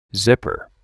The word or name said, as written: zipper